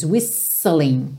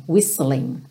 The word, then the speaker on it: whistling: É quase como se a gente tivesse dois S's, whistling, whistling, tá?